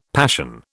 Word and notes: passion: British English

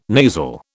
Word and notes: nasal: British English

